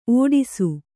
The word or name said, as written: ಒಟ್ಟೈಸು